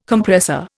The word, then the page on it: Compressor: Indian